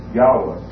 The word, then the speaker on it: Yahweh: Now, as we've already seen, the original pronunciation of this Tetragrammaton would have been Yahweh. That would have been the more accurate original pronunciation. We know that from other lines of investigation. But that would have been the accurate way to pronounce it.